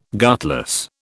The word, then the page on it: gutless: British English